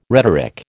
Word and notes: rhetoric: American English

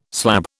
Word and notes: slab: British English

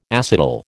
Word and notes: acetal: American English

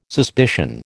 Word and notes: suspicion: American English

